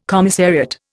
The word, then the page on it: commissariat: Indian